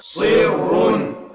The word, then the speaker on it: صر: صر. – صر هي بالصاد. الصاد هي اللون الازرق والراء باللون الازرق. صي صي صي ليست بالسين.